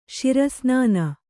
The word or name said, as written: ಶಿರಸ್ನಾನ